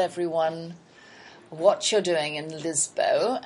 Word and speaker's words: Lisboa: Is that how they're pronouncing it, Lisboa? – Yeah. Well, that's how it's spelt, too. – Oh, okay. So I would imagine that your Brazilian friends would know that it was pronounced Lisboa.